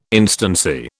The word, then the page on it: instancy: British English